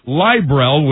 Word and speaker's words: Librel: Librel, which rhymes with libel, goes on sale this July.